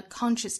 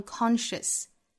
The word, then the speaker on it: conscious: That any, that, 若读成 that, 再跟后面的 any 做连读 that any conscious, 那 conscious 这个词和英音的区别在于第一个音节的元音，英音呢读成 conscious, 美音读成 conscious, 后面这个音节哈，英音和美音的读法是相同的，